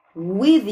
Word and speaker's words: with: Dancing in the dark with you. Ó, with, apesar de eu ter colocado um V, vocês vão fazer a pronúncia aqui, ó.